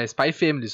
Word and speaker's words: Spy x Family: O nome do anime é igual o Hunter x Hunter, que o X é. é, mudo? – O X é mudo, sim senhor. Então é Spy Family só.